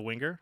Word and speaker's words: winger: Here's how Google pronounces winger. – Winger. – Winger. – That sounded like a hard G.